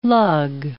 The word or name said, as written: lug